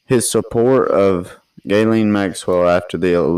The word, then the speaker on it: Ghislaine: It's pronounced Ghislaine. – Is it Ghislaine? – Yeah.